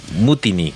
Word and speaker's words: Mutiny: es más fácil, es más fácil decir eh, Muniti que Mutini. Mutiny, claro.